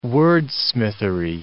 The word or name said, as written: wordsmithery